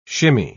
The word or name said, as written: shimmy